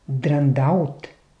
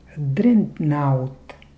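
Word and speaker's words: дредноут: Но някъде казват драндаут. Там вече е изменено. Не е така, както в английски. В английски е дрентнаут.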